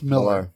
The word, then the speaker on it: Millar: Millar. – Actually, I think it's pronounced... It's is written it Millar, but I think it's pronounced Miller.